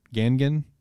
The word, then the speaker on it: Gangan: Full Metal Alchemist is apparently announcing a new project on July 12th, as well as getting the cover of the Shonen Gangan. Is that how you pronounce it? Gangan? – Yeah. – Okay, thank you.